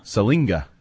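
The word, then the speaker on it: Siliga: And Sopoaga continues to just fall down the depth chart and be passed up by Silinga, despite the fact there's no N in it.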